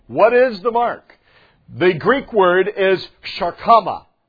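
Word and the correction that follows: charagma: Karagma, I should say. It's karagma. Better pronunciation.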